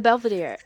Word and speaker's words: Belvedere: Yes, the Belvedere.